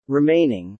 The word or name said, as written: remaining